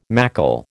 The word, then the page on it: mackle: American English